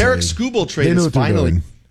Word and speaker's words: Tarik Skubal: How to say his name is, it's Tarik Skubal.